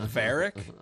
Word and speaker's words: Varrick: Verdick? V-A-R-R-I-C-K. – Verdick. – Verdick. – Where's the D coming from? Aren't you saying V-A-R-R? Isn't it Verrick? – You know what?